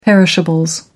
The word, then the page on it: perishables: American English